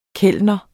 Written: KELNERNES

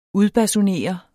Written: udbasunere

